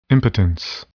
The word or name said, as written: impotence